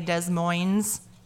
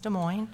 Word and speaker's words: Des Moines: Some people not from Iowa would probably say Des Moines, and it's not. It's Des Moines, so.